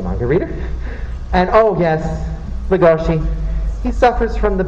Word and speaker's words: Legoshi: So for me, I'm always going to pronounce it as Legosi, but then when I watch the anime and they pronounce it right, Legoshi, I get so confused.